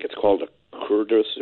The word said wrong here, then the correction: kudzu: The vine, the kudzu? – Yeah. – Yep. – How do you pronounce that? – Kudzu, K-U-D-Z-U. Yep.